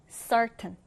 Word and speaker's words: certain: 첫 음절에 강세를 두고 certain.